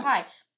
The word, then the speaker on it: PAI: INDIE-pendent, Coco Kind, Josh Rose Book, and then randomly Pi, like the PAI. Did I say that right? Yeah, yeah. Pi.